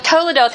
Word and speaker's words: toledot: Now we'll have some fun with toledoth. Have you ever heard of a toledoth? Okay, toledot, it's a Hebrew word, and it's pronounced toledot. Okay, that's the Hebrew word, toledot.